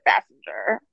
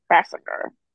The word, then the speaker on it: Basinger: Just saw like the Sean Hayes roasting Alec Baldwin and he pronounced it Bassinger. Bassinger.